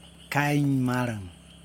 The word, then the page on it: kaj maraN: Yokoim